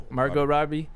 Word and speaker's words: Margot Robbie: Like, the scene of Margot Robbie, that's how you say her name, right? – Margot Robbie.